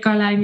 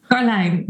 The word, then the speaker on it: Karlijn: In the Netherlands, we would say Karlijn Jacobs. But the IJ sound is something very native to the Dutch language.No other language in the world, as far as I know, is used to it. So I've seen at least 20 variations of spelling and even more pronunciation. So Karlijn, but anything close will work for me.